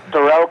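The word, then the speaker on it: Terell: I mean, Terrell Clark.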